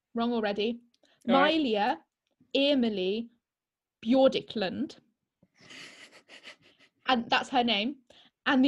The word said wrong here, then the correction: Bjorklund: She doesn't. I pronounced it wrong. Okay, let me try again. – All right. Okay, go on. – Mylia Emily Bjorklund.